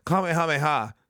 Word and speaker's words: Kamehameha: Kamehameha.